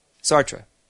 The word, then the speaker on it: Sartre: Sartre. I never know how to pronounce this name. That's why I didn't. – Jean-Paul Charles Aimard Sartre. – Whoa. – Sartre. All right. Thanks, Jay.